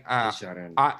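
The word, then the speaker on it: Desjardins: It looks like it's spelled Henry, but I know that's not how you say it. – It's like Desjardin. Like, Desjardin. Henry Desjardin. Henri.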